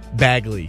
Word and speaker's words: Bagley: Bagley. – Bagley. – Okay. – Yeah. – So it's not the hard G.